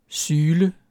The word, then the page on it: syle: dansk